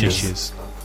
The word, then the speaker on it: knishes: Or is it just Knishes? – Knishes. Silent K. – Silent K.